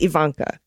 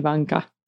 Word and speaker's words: Ivanka: Yeah, I guess Ivanka is the proper pronunciation. Yeah. – Ivanka.